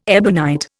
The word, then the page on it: ebonite: Indian